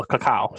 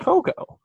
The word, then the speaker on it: cacao: So, because it's spelled C-A-C-O or C-A-C-A-O. So I'm like, that's cacao. Not, you're right, that's actually cacao. It's not cocoa.